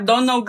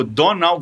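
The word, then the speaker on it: Domhnall: É, polícia da pronúncia tá passando por aqui para avisar que é Donald Gleason que fala o nome dele. Tem um H, tem um M, mas nenhuma dessas letras é pronunciada. Agora sim. – Como é que é? De novo? Donald Gleason? – É, não fala nem o M nem o H.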